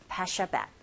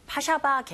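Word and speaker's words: Paşabağ: 이렇게 우리는 이제 영어 발음을 한다면 파샤백 이렇게 발음하는데 우리말로 이렇게 번역을 할 때 보면 파샤바 계곡 이렇게 부르더라고요. 참고로 알아두시고요.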